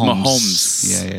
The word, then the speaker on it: Mahomes: How do you say his last name? – You said it right. – Mahomes? – Yeah. – Just like to mess with it. Well, there's an S at the end. – Oh, so Mahomes. It's Mahomes.